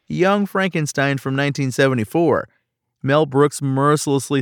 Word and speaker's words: Frankenstein: It's pronounced Frankenstein, who follows in his father's footsteps and creates a monster of his own.